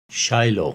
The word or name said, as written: Shiloh